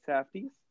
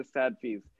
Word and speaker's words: Safdies: Um, the uh, so uh, Good Time is directed by the Safdies, um, who I've been uh, also pronouncing the Sadfies for some god awful reason.